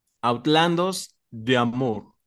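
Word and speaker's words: Outlandos d'Amour: Es de 1978 y es de The Police, su álbum debut, Outlandos de Amour. Sí lo busqué la pronunciación tal cual en francés, así se dice.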